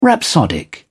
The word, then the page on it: rhapsodic: British English